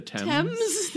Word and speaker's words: Thames: So the article starts, Britons fishing or scavenging in the River Thames. Is that how you pronounce that? – I think it's the Thames. – Thames? I'm like, that's not how you pronounce that. That's how it's spelled.